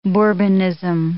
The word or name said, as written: bourbonism